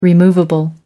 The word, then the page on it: removable: American English